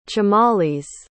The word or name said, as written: chimalis